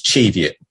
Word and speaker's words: Cheviot: Uh, and when I first spoke to him on the phone, actually, he was like, I asked him, where, where are these Cheviot Hills? And he was like, oh, it's pronounced Cheviot.